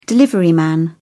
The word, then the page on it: deliveryman: British English